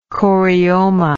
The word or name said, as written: chorioma